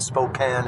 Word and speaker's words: Spokane: So I am now driving en route to Spokane, Washington, I've been saying Spokane the whole time, and Grandma told me I got to say it right, and Spokane is supposedly the right way.